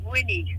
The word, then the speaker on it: Winnie: Yeah, I'm new, my name is Winnie. – Spell it, W-I-N-N-I-E.